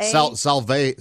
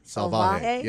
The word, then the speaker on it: Salvaje: Oh, S-A-L-A-V-A. – It's made of dog saliva? – Yeah. – How would you pronounce that? – Spit on my house. – S-A-L-A. – Salvaje. Salvaje. Yeah, S-A-L-V-A-J.